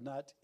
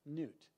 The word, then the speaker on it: Nut: It's actually spelled Nut, N-U-T, but it's pronounced Nut.